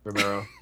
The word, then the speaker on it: Romero: Cesar Romero. Romeo, have you say his name? – Romero, yeah. The Joker.